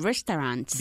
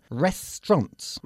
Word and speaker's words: restaurants: Se pronuncia restaurants. – Restaurants. – Eso es, con dos sílabas solo. – Okay. – Restaurants, ¿ok? La parte de en medio, la AU, se pierde.